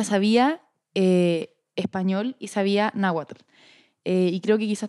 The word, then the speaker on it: náhuatl: Eh, él la ocupaba ella, o sea, ella era muy feliz narrando, pero también ella sabía náhuatl.